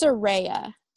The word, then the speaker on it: Soraya: So you have Soraya. Is that right? That's how you say it? Soraya? – I think it's Soraya. – Soraya. Okay. Yeah.